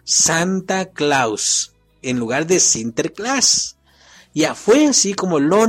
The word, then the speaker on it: Sinterklaas: Se pronunciaba así, Sinterklaas.